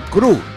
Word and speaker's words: Krü: Cru, K-R-U con diéresis.